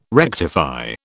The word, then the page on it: rectify: British English